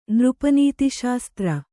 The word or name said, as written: ನೃಪನೀತಿಶಾಸ್ತ್ರ